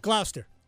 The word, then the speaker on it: Gloucester: Gloucestershire? Sure. – Gloucester. Just go Gloucester.